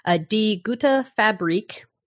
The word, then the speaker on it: Die Gute Fabrik: It's developed by a Danish studio, Die Gute Fabrik, which I believe I'm pronouncing correctly, or at least pretty close. – Sounds good.